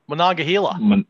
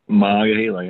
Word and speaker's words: Monongahela: Monongahela. – Monongahela, yeah.